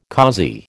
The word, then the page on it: causey: American English